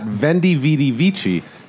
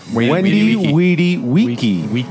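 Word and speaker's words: Veni, vidi, vici: And I'm going to say that V's are pronounced as W's in Latin. So it's not Vendi, Vidi, Vici. It's Wendy, Weedy, Weeky.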